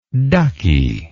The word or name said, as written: duckie